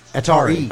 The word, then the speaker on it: Atari: But you do have to pronounce the second I. It's two eyes. – Atari.